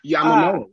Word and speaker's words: Yamamoto: Oh, God, I can never say his name. It starts with a Y. I want to say Yamamoto, but I don't think that's right. – Yamamoto, Yes, thank you.